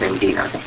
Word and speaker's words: Ngina: and they've called Chicago and asked for her, Angina, N-G-I-N-A, pronounced Angina.